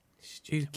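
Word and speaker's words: Stiffkey: Stewky? – Which is spelt Stiffky Blue, but obviously anyone knows that you pronounce it Stewky Blue.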